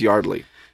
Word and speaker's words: Yeardley: Is it Yeardley or is it Yardley? Yeardley Smith? – I think it's Yardley.